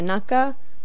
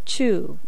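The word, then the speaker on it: naka (中): Well, today's kanji is the kanji for middle or inside, which in Japanese is pronounced either naka or chu.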